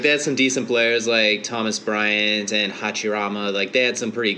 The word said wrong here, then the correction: Hachimura: I just butchered his name. I don't know. Yeah, Hachimura. – Hachimura.